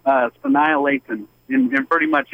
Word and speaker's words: Sanaa Lathan: Sanaa Lathan. – You're going to have to spell that for me, big dog. – S-A-N-A-A-L-A-T-H-A-N. – Oh, yeah. F-A-N-N. – S-A-S-A-N-A.